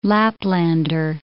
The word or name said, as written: Laplander